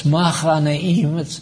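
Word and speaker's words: Mahanaim: And that's what the Hebrew word, it's machanaim, it's spelled like mehanaim, and it means the company of two angels.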